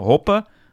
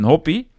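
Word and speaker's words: Hoppe: Ik had 3 verschillende uitspraken voorbij horen komen. Hoppen, hop en hoppie. Maar ik werd door Martijn Visser, fervent Duitslandvolger, op de echt Amerikaanse uitspraak gewezen. Nou, dat is hoppie.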